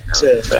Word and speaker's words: Scuffet: Tutta la sera glielo dico, gli dici si chiama Scuffet, non è francese e non si chiama Scuffet. – Scuffet, no, è friulano.